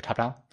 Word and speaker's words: Toptal: I should actually pronounce it very carefully. I'm like eating my words here. TopTal. It's top as in top of the mountain, tal as in talent.